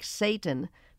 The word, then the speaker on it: Seton: Combined with the fact that Seton's name is being pronounced more like Satan, which is an accepted pronunciation, Macbeth's familiarity with the murderers is just one more indication of his descent into almost total darkness.